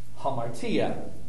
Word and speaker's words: hamartia: the A is pronounced with the sound, the aspirant H, hamartia.